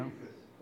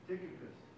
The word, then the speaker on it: Tychicus: I think, if I read it right, also to Ephesus, and it was about 1,000 miles that he sent these guys, and I don't think I pronounced his name right, Tychicus, anybody know? – Tychicus. – What? – Tychicus. – Yes, exactly.